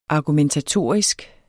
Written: argumentatorisk

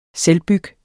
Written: selvbyg

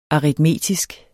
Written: aritmetisk

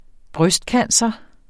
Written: brystcancer

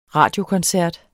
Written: radiokoncert

